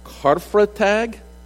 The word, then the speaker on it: Karfreitag: We have a visitor from Germany here this morning, and I understand, and correct me if I say this wrong, that in Germany it's called Karfreitag. I said it right? Pretty close?